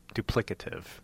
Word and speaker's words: duplicative: I must have said it in a way that was, I put the emphasis somewhere else, like duplicative. Duplicative.